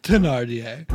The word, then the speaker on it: Thénardier: I think it's spelled Thénardier, but yes, probably pronounced Thénardier.